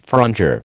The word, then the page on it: frondeur: American English